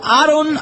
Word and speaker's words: Aaron: Aaron, claro, con doble A se escribe Aaron. – No, por Aaron Aparicio. – Aaron Aparicio, doble A, sí.